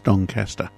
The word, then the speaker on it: Doncaster: That's actually, if you're in Melbourne, it's Doncaster. – Really? – That's how they pronounce it, yes.